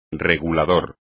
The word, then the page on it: regulador: espagnol